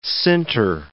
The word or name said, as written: sinter